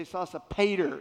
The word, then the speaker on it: Sosipater: If you're in East Tennessee, you say Sosipater. It rhymes with tater.